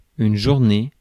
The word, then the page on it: journée: [ʒuʁ.ne]; France